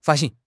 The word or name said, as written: 发行